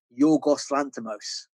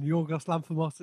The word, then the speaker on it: Yorgos Lanthimos: Like, is that how you pronounce it? Yeah, yeah, yeah. yeah. I up? – As a Greek man, Yorgos Lanthimos is is is perfect pronunciation.